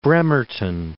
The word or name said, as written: Bremerton